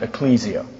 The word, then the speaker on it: ekklesia: The Greek word is ekklesia. Some people pronounce it ecclesia.